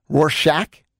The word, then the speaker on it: Rorschach: Rorschach test. – Rorschach? – Yep. – It's Shaq at the end? I always avoid the word because I'm never confident in it. Rorschach test.